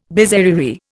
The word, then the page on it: bizarrerie: Indian